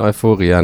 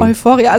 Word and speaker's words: Euphoria: Ich glaube, ich würde ihn Euphoria nennen. – Euphoria, also deutsch, ja? Euphoria.